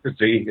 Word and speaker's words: Frazee: Frazee, yeah.